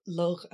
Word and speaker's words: Lugh: Suffice it to say that Lu, or Lugh as it would be pronounced in old Irish, but most people are familiar with him as Lu, was the hero of the Tuatha Dé Danann.